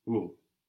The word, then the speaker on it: au: Au village. – Something very strange happens here. A and le become au, which is pronounced like au. Au.